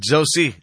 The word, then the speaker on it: Josie: Parang to si Mama, si Sir Manny Jan Agbay, kaganina murag na, kuanang na naipit lang yung dila, na Jose niya. Pero ang yung pag-pronounce naman, Josie. Juicy. Kay kuan man, G-O-S-I-E.